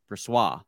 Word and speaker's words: Brossoit: The Hockey Reference phonetic pronunciation is Laurent Brossoit. – Laurent Brossoit. Brossoit. – There you go. You keep trying to throw in a W in there.